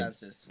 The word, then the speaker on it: Betances: Betances.